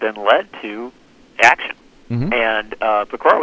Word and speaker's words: Pecora: Your publisher, Random House, notes that in the book, you call you close with a call for a version of the Pecora Commission. – Yep. – Is that Pecora.